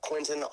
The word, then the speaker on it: Quentin: Quentin Overcomer.